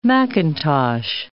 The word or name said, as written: mackintosh